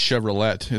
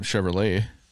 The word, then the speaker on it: Chevrolet: Well, like our Chevys, when you pronounce the full name of it, it's Chevrolet. It's not Chevrolet.